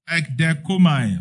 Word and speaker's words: ekdechomai: Waited, pronounce that one for me. Ekideko. Huh? Wow, you have, there's no idea. Okay, ekdekomai. Okay, thank you. As waited, it means expecting. Ekdechomai.